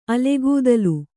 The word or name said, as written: ಅಲೆಗೂದಲು